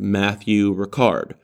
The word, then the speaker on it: Matthieu Ricard: In fact, they cited this fascinating study done with a French monk named Matthieu Ricard, pronounced with a French accent.